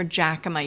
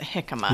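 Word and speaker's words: jicama: and if you're going to the grocery store and you're looking for jackama, you should probably pronounce it hickama.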